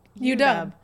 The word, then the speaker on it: UW: that you put the emphasis on the U... – UW. – No, there you go. Then you did it right.